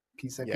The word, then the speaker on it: Pisapia: Pisapia.